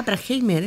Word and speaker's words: Bruckheimer: Y en la producción el legendario Jerry Bruckheimer, ¿lo he dicho bien? – ¿Tu amigo Jerry Bruckheimer? – Ah, Bruckheimer es.